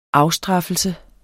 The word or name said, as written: afstraffelse